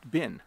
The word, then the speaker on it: been: It sounds funny to my ear, because when we speak quickly, the word been actually is pronounced bin.